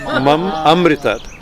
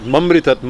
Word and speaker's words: mam amritat: Mom, better to give to overemphasize the long A. Mam amritat, not mamritat. Mam mritat means kill me. Mam amritat means grant me immortality.